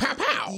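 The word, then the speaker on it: Pau: Two-time NBA champion Paul Gasol. Is that how you say it? Paul. I always say Paul. Powell? – You like pow. You want to call him Paul? – Yeah, Paul.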